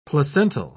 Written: placental

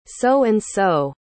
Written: so-and-so

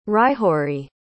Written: hryhoriy

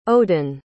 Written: odon

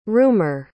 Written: rumer

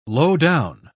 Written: lowdown

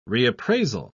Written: reappraisal

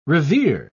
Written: revers

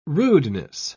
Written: rudeness

